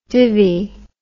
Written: divi

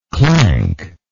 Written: clankless

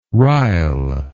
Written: ryle

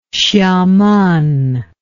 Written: Hsiamen

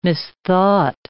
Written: misthought

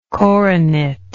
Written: coronetlike

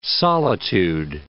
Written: solitude

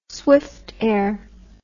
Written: swiftair

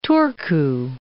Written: Turku

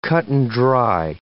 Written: cut-and-dry

